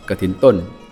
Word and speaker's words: กฐินต้น: กระถินต้น